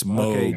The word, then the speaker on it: Moog: Moog. That's right. Everyone says it wrong. Everyone says Moog. It's Moog.